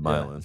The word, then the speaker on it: Milan: Spelled like Milan. – Should be Milan, but Milan. – Yeah, it's called Milan. It's the same thing. I think like my land, but without the D.